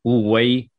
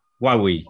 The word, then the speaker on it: Huawei: Siempre la escuché pronunciado en forma distinta, nunca supe bien cómo se dice. Huawei. – Huawei.